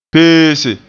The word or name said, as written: peese